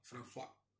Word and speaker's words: François: Eh, que él vive con Vane, que es eh, protagonizada por Débora Francois, ¿no? – François. – François perdón mi, pronunciación.